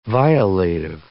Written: violative

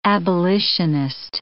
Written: abolitionist

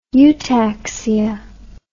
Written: eutaxia

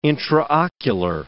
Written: intraocular

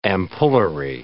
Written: ampullary

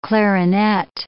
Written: clarinet

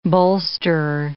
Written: bolsterer

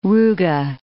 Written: ruga

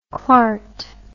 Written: clarty

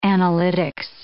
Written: analytics

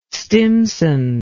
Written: stimson

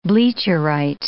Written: bleacherite

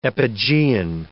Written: epigean

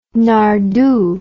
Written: nardoo

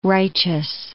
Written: righteous

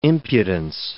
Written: impudence

